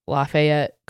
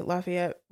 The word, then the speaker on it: Lafayette: You said what I say, Lafayette. Robbie told me that we're, that that's not the right way to say it. He says Lafayette. But there's an A.